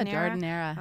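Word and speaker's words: giardiniera: I have a I'm going to probably pronounce it wrong. Jardinera? – Yeah, Jardinera. – Okay, I always say Gardinera.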